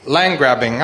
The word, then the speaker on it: land grabbing: Land grabbing. – Oh, that was English. All right, sorry. – Come si dice? – Land grabbing. No, land grabbing. Hai detto giusto.